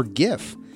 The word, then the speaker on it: GIF: Is it pronounced GIF or GIF? He told the New York Times in 2013, the Oxford English Dictionary accepts both pronunciations. They are wrong. It is a soft G, pronounced GIF.